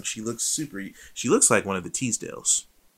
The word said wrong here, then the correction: Tisdale: You know, like Ashley Teasdale. – Tisdale. – Tisdale? I say Teasdale. I had neighbors. Their names were spelled the same, but they pronounced it Teasdale.